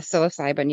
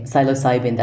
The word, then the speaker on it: psilocybin: Yeah, psilocybin, yes. – Okay, psilocybin, that's how you pronounce it.